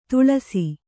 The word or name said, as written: ತಿಳಿಸು